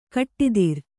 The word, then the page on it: ಕಟ್ಟಿದಿರ್: kaṭṭidir; Kannada